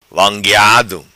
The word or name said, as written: vangueado